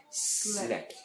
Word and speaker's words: slept: Cuidado de no pronunciar una E antes de la S. ¿Recuerdan? Slept.